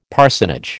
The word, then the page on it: parsonage: American English